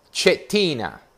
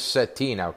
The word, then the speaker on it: Cettina: I'm going to pronounce this Roger in Italiano. Cettina. – Yeah, you're very good. – Roger Cettina. And here in America, they pronounce it Cettina, correct? – Correct.